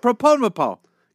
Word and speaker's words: propofol: And whatever, I mispronounced the drug, I don't care. Okay. – Propofol. – Inside joke explained. – It's propofol.